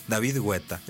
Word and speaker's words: Guetta: En Francia, así en su país natal, se dice David Guetta, es correcto.